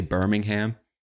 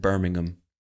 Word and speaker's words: Birmingham: Americans would probably say Birmingham, because that's how we say. There's a city called Birmingham in Alabama in the United States. In England, they pronounce it Birmingham, in the Midlands of England, if I'm not mistaken.